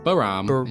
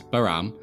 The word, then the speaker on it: Behram: Behram. It's Behram. Did the research on the pronunciation of this one.